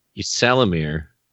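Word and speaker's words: Ysalamiri: Wait, how do you pronounce that word again? – Yislarmy, Yislamy, I don't know. – What is that? – Yisalamir. – Yisalamir, whatever.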